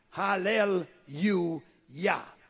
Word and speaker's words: Hallelujah: And we say Yah because there's no J in the Hebrew language. So the J is pronounced Y. Hallel, you, Yah.